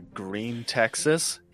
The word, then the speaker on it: Gruene: Yeah, what, you want to go to Green, Texas? Yeah, how do you spell that, idiot? Stupid. – Green Texas. – Yeah. G-R-U-E-N-E. Green Texas. – It's pretty close to the German word for green.